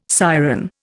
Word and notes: siren: Indian

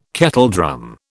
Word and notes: kettledrum: British English

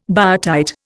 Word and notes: biotite: Indian

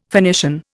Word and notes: Phoenician: Indian

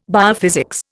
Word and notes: biophysics: Indian

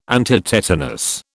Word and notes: antitetanus: British English

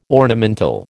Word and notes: ornamental: American English

